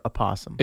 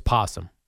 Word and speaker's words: possum: And do we say possum or opossum? – It's possum.